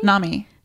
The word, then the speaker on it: NAMI: NAMI, yes.